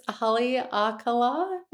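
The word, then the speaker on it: Haleakalā: And I struggle with Hawaiian pronunciation, so I'm going to do my very best job at this one. I think it's Haleakala National Park.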